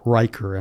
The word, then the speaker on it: Riker: Riker. And I'm, I'm hoping I'm pronouncing that last name correct. It's R I K E R, which I believe is Riker.